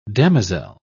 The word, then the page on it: damozel: British English